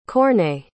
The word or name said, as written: courtnay